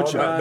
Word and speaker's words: Okja: Okja.